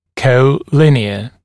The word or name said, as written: colinear